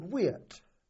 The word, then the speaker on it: Weert: And we pulled back to a village in Holland called Weert. – How do you spell it? W-E-E-R-T. W-E-E-R-T.